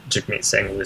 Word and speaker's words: Jagmeet Singh: Yeah, I would be quite surprised to see Jagmeet Singh lose his riding. – Excellent pronunciation there. Your dad screwed it up 3 or 4 times in a row.